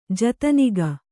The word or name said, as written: ಜತನಿಗ